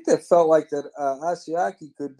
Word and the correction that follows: Asiasi: Is that how you say it? – Asiasi, yes.